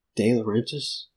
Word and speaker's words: De Laurentiis: So this was Dino De, La- De Laurentiis? Am I saying that right? – Yeah.